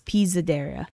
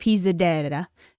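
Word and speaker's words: Pisadeira: It's just that the S is more Z-like, so it's Pisadera. They say it a lot nicer. They say it like Pisadera.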